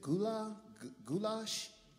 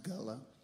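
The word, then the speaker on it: Gullah: what's that music? Goulash? It's Gulla. It's pronounced Gulla, and it is uniquely from the coastal islands of the East Coast.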